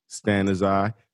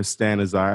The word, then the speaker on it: Stanizai: Stanizai. Sara Stanizai. I'm here with Sara Stanizai, who is a marriage and family therapist in Long Beach.